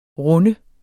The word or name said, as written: runde